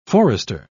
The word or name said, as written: forester